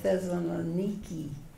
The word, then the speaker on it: Thessaloniki: Now, if you want the correct pronunciation, it's today they call it, the Greeks call it Thessaloniki.